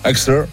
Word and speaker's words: Exeter: On le prononce pas, c'est comme Leicester. – Leicester. – Exeter.